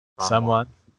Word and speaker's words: Samhain: Samhain, is that how you say it? – Samhain.